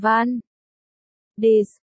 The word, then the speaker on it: Van Ness: Tiện thể xin nói cho cô nhớ là chú ý phát âm từ này thành Van Ness, nếu không, dân địa phương sẽ hỉnh mũi cười chúng ta.